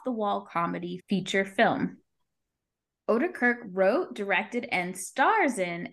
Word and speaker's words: Oedekerk: Is that how you say his name? – I actually I thought it was Odenkirk, but I think you actually probably said it right. Oedekerk.